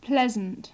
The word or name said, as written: pleasant